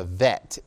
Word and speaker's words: bet: And that's what would denote the Hebrew word bet. And if there is no dot in the center of it, then that tells you that that's a pronunciational change, and it would sound more like a V, a vet, if you will.